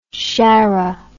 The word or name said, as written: chara